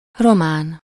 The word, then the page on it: román: /ro.maːn/